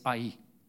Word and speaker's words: Ai: The next city is Ai, or Ai, as some people would pronounce it, Ai.